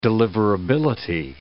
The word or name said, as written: deliverability